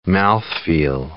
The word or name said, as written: mouthfeel